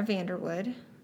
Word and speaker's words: Vanderwoude: Tara Vanderwoude.